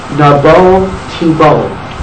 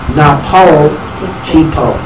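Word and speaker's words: Nabol Tibol (נבל תבל): So he told Moshe, Nabol Tibol, which means you shall be worn away. So the Maggid quoted that pasuk, but he pronounced a pay instead of a bet. Napol Tibol, Napol, Napoleon Tibol shall fall.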